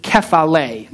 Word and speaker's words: kephale: So the word head here in this passage is the Greek word kephale. Now, I don't have it on the screen. It's spelled K-E-F-A-L with a long E. The accent's on the last syllable.